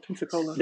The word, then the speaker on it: Pensacola: Is that how you pronounce it? Is it Pensacola? – That is, yeah.